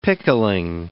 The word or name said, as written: pickling